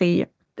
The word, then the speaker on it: Re: Ganz kurz, warum kennt man auch Ra? Ist das das englische Wort dafür oder spricht man es eigentlich Re aus? – Das ist ein Problem, weil die Hieroglyphenschrift ja eine vokalisierte Schrift ist, eine Vokalisationsschrift ist. Es ist eine Konsonantenschrift, ja? Deswegen die A, E, I oder U wissen wir nicht genau. Und wir lesen es Ra oder Re, damit wir es aussprechen können.